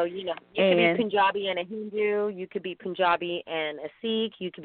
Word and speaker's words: Punjabi: We said you were East Indian, but more specifically, you are Punjabi.